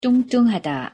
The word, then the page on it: 뚱뚱하다: Korean